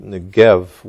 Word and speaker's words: Negev: Today it's pronounced Negev with a V.